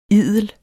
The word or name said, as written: idel